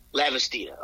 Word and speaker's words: Lavastida: Is it Lavastida.